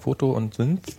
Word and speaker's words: Photosynth: Ich habe übrigens mal, ich habe es erstaunlich schnell rausgefunden, die Software heißt Photosynth, Synth, Synth, wie wird das ausgesprochen? Foto und Synth.